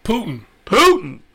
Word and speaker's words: Putin: Putin.